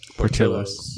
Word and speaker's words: Portillo's: Is that how you say it? – Portillo's.